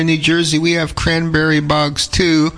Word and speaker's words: Cranbury: They spell it C-R-A-N-B-U-R-Y, cranberry. A lot of people call it cranberry, in reference to the cranberry bogs.